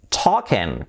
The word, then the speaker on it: talking: I am talking. Cuidado esta palabra, talking, la L fuera, la omitimos. Da igual que sea inglés americano, inglés británico, inglés australiano, la L esa no se pronuncia. Talking, talking.